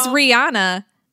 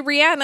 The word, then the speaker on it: Rihanna: Everybody says Rihanna, and it's actually Rihanna, and I try to respect that, but I'm not a jerk, and I don't correct everybody else.